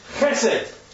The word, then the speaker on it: chesed: It's hard to pronounce unless you're Scottish. So you Scots who can pronounce loch, ch, not as a ch, but as a ch, right? The word is chesed.